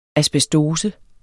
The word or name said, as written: asbestose